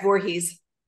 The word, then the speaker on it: Voorhees: Did I say that right? Voorhees? – It is. Yep, that's correct. Voorhees.